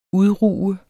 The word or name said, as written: udruge